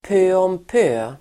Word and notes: pö om pö: pö:åmp'ö:

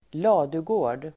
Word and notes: ladugård: [²l'a:dugå:r_d]

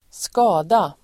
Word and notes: skada: [²sk'a:da]

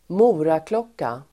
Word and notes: moraklocka: [²m'o:raklåk:a]

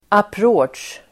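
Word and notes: approach: [apr'å_otsj]

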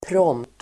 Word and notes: prompt: [pråm:pt]

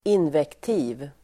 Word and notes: invektiv: [invekt'i:v]

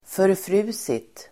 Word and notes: förfrusit: förfr'u:sit

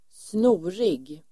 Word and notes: snorig: [²sn'o:rig]

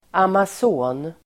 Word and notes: amason: [amas'å:n]

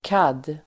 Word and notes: CAD: [kad:]

